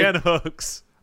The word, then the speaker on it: Hooks: Is that how you pronounce Jan Cooks? – Jan Cooks, yep. – Or Jan Hooks, sorry. – Jan Hooks.